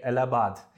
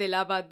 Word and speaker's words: Allahabad: I would interchangeably say Allahabad, Allahabad because you know Allahabad is the uh, Hindi and in Urdu pronunciation, and Allahabad is how it's written in uh, English.